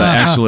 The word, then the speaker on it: Axle: So Axel it is. – It is a fantastic name. – Thank you. It's spelled as in the car Axel, A-X-L-E, as opposed to the German spelling, which will be E-L.